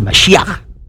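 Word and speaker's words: Mashiach (משיח): You see, the word Christos is the Greek translation of the Hebrew Mashiach, and that's the way they pronounce it, Mashiach.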